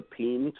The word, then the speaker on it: paean: And one thing that we actually wrote in 2005, and I put it on the list, is called A Peen to Winter. And peen is spelled P-A-E-A-N.